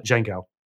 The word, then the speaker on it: Django: Django. – Yeah, yeah, I don't know why I pronounced the D.